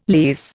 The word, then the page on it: lease: Indian